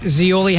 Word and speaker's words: Zeoli: It's me, Rich Zeoli, hanging with you today. I have my podcast. You can check it out wherever you get your podcast. Z-E-O-L-I. That's how you spell it. Just like the Olive Garden.